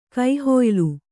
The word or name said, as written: ಕೈಹೊಯ್ಲು